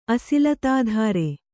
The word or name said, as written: ಅಸಿಲತಾಧಾರೆ